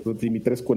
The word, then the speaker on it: Dimitrescu: así, se, pronuncia Dimitrescu en rumano.